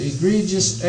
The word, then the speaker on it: egregious: Spelled E-G-R-E-G-I-O-U-S. You pronounce the first E, egregious.